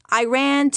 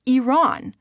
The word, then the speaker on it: Iran: The correct pronunciation, I always inform them, is Iran. Iran is a sentence, I told them, as in, "I ran away from my geography lesson."